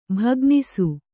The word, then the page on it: ಬಿಕನಾಸಿ: Kannada